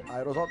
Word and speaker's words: aerossol: Aerossol tem mais. – A forma correta de escrita e pronúncia da palavra em português é aerossol.